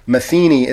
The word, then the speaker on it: Matheny: And then my last name, Matheny, is spelled M-A-T-H, like math, E-N-Y. It's different spelling from Pat and Mike Matheny. It's M-A-T-H.